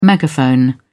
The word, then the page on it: megaphone: British English